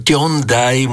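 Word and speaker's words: Tyondai: Tiondai musím tak výrazne vyslovať, aby si náhodou niekto nemyslel, že to je Tony Braxton.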